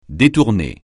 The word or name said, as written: détourné